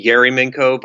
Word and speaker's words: Ayerimenko: You were pretty close. It's technically Ayerimenko, but nobody gets that, and half of people spell it with an I.